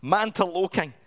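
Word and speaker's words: Mantoloking: Mantaloking.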